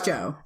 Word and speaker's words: Joe: Is it Joe? – It's Joe.